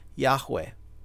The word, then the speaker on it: Yahweh: to Yahweh, right, or Yehovah, depending on how you want to pronounce it.